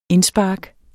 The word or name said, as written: indspark